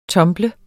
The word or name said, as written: tumble